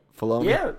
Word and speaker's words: Filoni: And he doesn't have as far as we know, Filoni... I'm saying it right? Filoni? – Yeah.